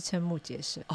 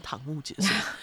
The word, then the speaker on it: 瞠目结舌: 之后开始了一连串堂，是瞠目结舌吧？ – 对，是瞠目结舌哦，差点念成瞠目结舌。